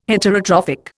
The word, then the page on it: Heterotrophic: Indian